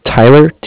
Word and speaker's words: Tyler: My name is Tyler, T-Y-L-E-R, and I am calling from Bank of Cardiff. It's spelled C-A-R-D-I-F-F, as in Frank, Frank.